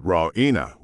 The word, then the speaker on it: Raina: Raina, she pronounces it Raina with the stress on the E.